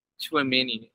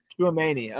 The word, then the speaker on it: Tchouaméni: I'm probably butchering this name, but Aurelien Tchouaméni? – Tchouaméni, I think. – Tchouaméni, okay.